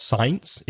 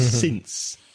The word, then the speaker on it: since: So be careful of the difference between since and for and the pronunciation of since, which is not science. It's since with a short vowel sound, I, since 2014.